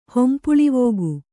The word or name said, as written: ಹೊಂಪುಳಿವೋಗು